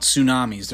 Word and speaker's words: tsunamis: You did understand that it starts with a silent T, right? – Yeah, I knew that. – Yeah. Kind of, they're like crazy. Tell the peeps about tsunamis.